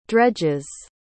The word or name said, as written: dredges